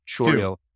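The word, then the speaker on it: Chourio: Churio.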